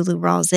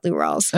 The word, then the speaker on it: Lou Rawls: I'm not shitting on Lou Rawls, but do you know any other, what do you know about Lou Rawls? – I know it rhymes with poo balls.